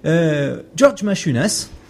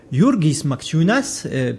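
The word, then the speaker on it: Jurgis Mačiūnas: George Machunas, qui est, je devrais pas du tout prononcer son nom comme ça, on devrait plutôt dire Jurgis Machunas, puisque c'est un Lituanien d'origine, qui a cherché à construire une théorie, etc.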